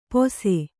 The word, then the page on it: ಪೊಸೆ: pose